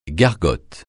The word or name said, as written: gargote